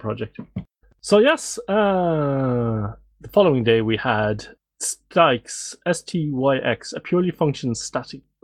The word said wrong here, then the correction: Styx: Your pronunciation threw me slightly there because I would pronounce it sticks to rhyme with Nix.